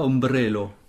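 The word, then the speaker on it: ombrelo: Très bien prononcé. Il y a l'accent tonique. Très bien. Ilia ombrello.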